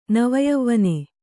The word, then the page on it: ನವಯವ್ವನೆ: nava yavvane